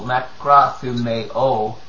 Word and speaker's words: makrothumeo: And let me pronounce that correctly. Makra, not O, makrothumeo, or makrothumia.